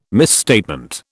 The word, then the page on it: misstatement: British English